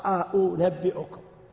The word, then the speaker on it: أأنبئكم: لكن الذين يسهلون سهل الهمزة الثانية قريبة من الواو، أأنبئكم، أأنبئكم. فهو لا ينطقها همزة واضحة ولا ينطقها واو، ما يقول أأنبئكم، لا، فيها همز مسهل.